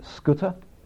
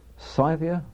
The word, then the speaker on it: Scythia: Or, as some people like to pronounce it, Scuta. Scythia, I mean, it seems to have no correct pronunciation.